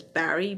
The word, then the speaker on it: Barry: And that's Frances spelled with F-R-A-N-C-E-S. My last name is Barry, B-A-R-R-Y.